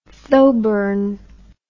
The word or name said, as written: thoburn